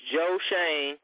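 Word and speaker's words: Joe Schoen: It is Joe Shane, and I know. And that's how we got to pronounce it because he is our general manager now.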